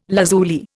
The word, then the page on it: lazuli: Indian